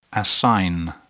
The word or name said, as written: assign